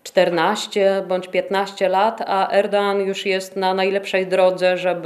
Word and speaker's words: Erdoğan: Ten, który rządzi Turcją aktualnie, czyli prezydent Recep Tayyip Erdoğan, ja od razu powiem, ponieważ ktoś mi zarzucił, że źle wymawiam jego nazwisko. Otóż nazwisko wymawia się naprawdę Erdoğan, a nie Erdoğan. To jest taka literka w tureckim alfabecie, tak zwane Yumuşakge i ono jest takie niewymawialne, po prostu się je połyka. Więc jest to prezydent Erdoğan, a nie tak jak często słyszymy Erdoğan.